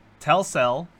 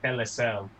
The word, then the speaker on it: Telcel: So, Telcel, Chelsea FC, NEC, and Claro, which is weird because Telcel. You're not pronouncing that first one right. It's Telcel. – Telcel.